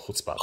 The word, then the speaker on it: chutzpah: First of all, well done on your pronunciation of chutzpah, very hard word.